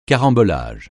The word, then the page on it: carambolage: francais